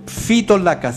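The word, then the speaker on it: Phytolacca: Se escribe PHY Tolaca con 2 C. Fitolaca se pronuncia.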